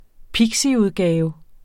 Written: pixiudgave

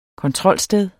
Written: kontrolsted

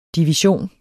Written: DIVISIONEN

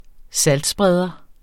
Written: saltspreder

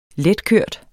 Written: letkørt

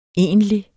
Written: egentlig

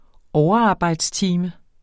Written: overarbejdstime

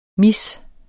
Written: mis

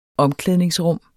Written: omklædningsrum

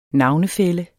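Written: navnefælle

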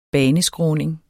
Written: baneskråning

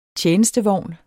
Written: TJENESTEVOGNE

